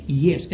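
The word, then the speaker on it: jes: La J no está, la J en Esperanto se lee Y y se lee yes.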